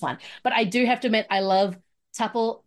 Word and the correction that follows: Tuppence: Tuppis, Tuppel, Ack, ah, not say her name, um, Tuppence Middleton's performance, who's also doing soft spoken in this as well.